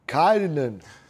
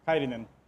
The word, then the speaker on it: Κάιρινεν: Και ο Κάιριν τονίζεται στο πρώτο αλφα. – Κάιρινεν. – Ως Φιλανδός. Κάιρινεν.